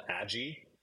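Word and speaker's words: Adjy: Well, I mean, one of my favorite albums from the last couple of years is a band called Adji, who I only recently figured out that it's pronounced that way. It's A-D-J-Y.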